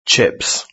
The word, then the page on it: chips: British English